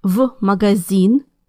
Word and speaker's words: в: Now, it's hard to say «ф» магазин, so Russians pronounce it as the letter «v» instead. Listen again. – В магазин.